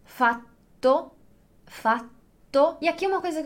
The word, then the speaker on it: fatto: Então, se você falar il fato e non il fatto, è ovvio che una persona riesce a comprendere.